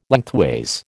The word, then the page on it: lengthways: American English